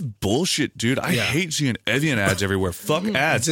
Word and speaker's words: Evian: It's pronounced Evian. – Evian. – It's a French town.